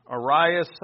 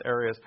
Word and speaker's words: Arius: One of the first and most influential is Arius, or Arius, I think it's pronounced Arius.